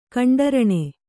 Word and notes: ಕಂಡರಣೆ: kaṇḍaraṇe

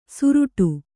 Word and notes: ಸುರುಟು: suruṭu

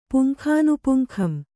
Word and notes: ಪುಂಖಾನುಪುಂಖಂ: kannada

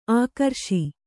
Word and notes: ಆಕ್ರೋಶ: ākrōśa

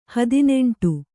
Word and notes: ಹದಿನೆಂಟು: hadineṇṭu

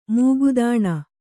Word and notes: ಮುಗದಾಣ: Kannada

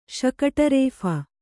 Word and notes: ಶಕಟರೇಫ: Kannada

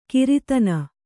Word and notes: ಗ್ರಥನ: grathana

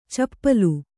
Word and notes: ಚಪಲೆ: Kannada

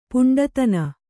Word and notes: ಪಿಂಡಾದಾನ: piṇḍādāna

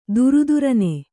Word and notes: ಥರಥರನೆ: thara tharane

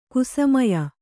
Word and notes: ಕುಸಮಯ: kusamaya

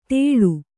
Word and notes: ತೆಳು: teḷu

